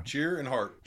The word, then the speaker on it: Tschirhart: Yes, Tschirhart. Yep. – Like cheer. – Cheer and heart.